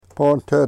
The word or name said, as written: pontet